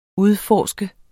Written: UDFORSKET

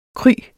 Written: kry

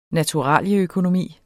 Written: naturaløkonomi

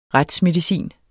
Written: retsmedicin

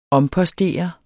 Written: ompostere

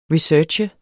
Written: research

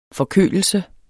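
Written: FORKØLELSEN

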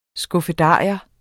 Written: skuffedarium

